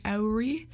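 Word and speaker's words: Ouri: After that, we have Daisy Cutter by Auri. That's O-U-R-I.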